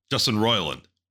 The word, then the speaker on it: Justin Roiland: Justin Roiland.